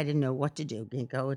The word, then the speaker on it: ginkgo: And, you know, like after the is it pronounced ginkgo or jinko? I think it's the ginkgo tree. – Oh, yeah, ginkgo.